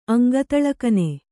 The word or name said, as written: ಅಂಗತಳಕನೆ